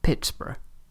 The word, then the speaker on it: Pittsburgh: As the settlement grew, it became a town, Pittsburgh, but spelt in the Scottish faction, like Edinburgh. With a small change in pronunciation, this becomes Pittsburgh.